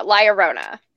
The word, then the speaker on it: La Llorona: There's a yeah sound in there, and I can never say it right. La Llorona. – La Llorona.